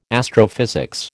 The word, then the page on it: astrophysics: American English